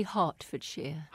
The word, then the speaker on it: Hertfordshire: It's actually Hertfordshire.